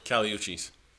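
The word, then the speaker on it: Kali Uchis: How do you say her name? – Caliuchis.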